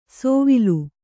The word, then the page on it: ಸೋವಿಲು: sōvilu